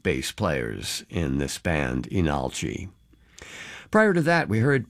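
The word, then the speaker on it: Leenalchi: like Lee Nalchi, but pronounced Enalchi.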